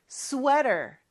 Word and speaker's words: sweater: 스웨터 영어이긴 한데 발음은 조금 다르죠? sweater, sweater 이렇게 발음해 주세요.